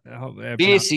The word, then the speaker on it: Spiess: Spiess, you got it right the first time.